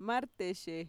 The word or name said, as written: marteshe